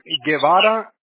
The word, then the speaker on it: Guebara: That's Mr. Guevara.